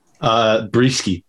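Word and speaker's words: Brieske: I think the Rays are going to be able to get to, am I pronouncing it right? Breesick? Is that how you say it? – Breesky. It's Breesky or Brisky, either way.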